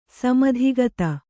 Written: ಸಮಧಿಗತ

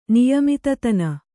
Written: ನಿಯಮಿತತನ

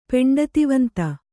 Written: ಪೆಂಡತಿವಂತ

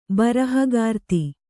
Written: ಬರಹಗಾರ್ತಿ

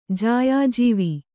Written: ಜಾಯಾಜೀವ

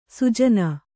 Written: ಸುಜನ

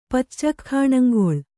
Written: ಪಚ್ಚಖ್ಖಾಣಂಗೊಳ್

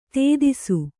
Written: ಧೂತಿಸು

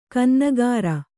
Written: ಕನ್ನಾಗರು

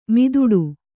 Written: ಮುದುಡು

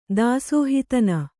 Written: ದಾಸೋಹಿತನ

